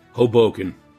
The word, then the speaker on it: Hoboken: Is that how you say it, Hoboken? – Hoboken. – All right. I got that one right, folks.